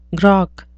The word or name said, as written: grok